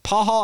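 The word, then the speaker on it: Pachall: Casey Pascal, I think it was how you, Pahal, I remember how you pronounce his last name.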